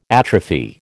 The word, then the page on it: atrophy: American English